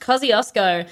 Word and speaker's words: Kosciuszko: And it is, it is spelled, Tom, you spelt it wrong, but that is fine because it is pronounced Kosciuszko. – Yeah. – And it is spelled K-O-S-C-I-U-S-Z-K-O.